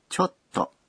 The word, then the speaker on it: ちょっと: ちょっと是有点儿。ちょっと表示委婉的拒绝。ちょっと后面省略了不行这样的表示否定的说法。故意不说出来是为了使拒绝的口气不至于太强烈。发音的时候请在ちょ的后面停顿一拍。 – ちょっと对吧？ – 对。